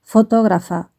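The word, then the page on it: fotografa: Spain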